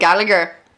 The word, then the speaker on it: Gallagher: The Irish proper pronunciation of his name. – Oh, instead of Gallagher.